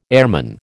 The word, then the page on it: airman: American English